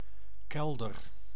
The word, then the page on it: kelder: IPA: [kɛl.dœɾ]; Dutch